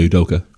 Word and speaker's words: Udoka: There was a foul call, but there was some words exchanged between M.A. Aduka. – Yeah, Udoka. – Adoka. Mm-hmm. I always say his last name wrong.